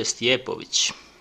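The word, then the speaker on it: Stipovich: Aquí mi invitado le estoy pasando el nombre para que lo pronuncie como debe de ser. Eh, su nombre es George Stiepovich.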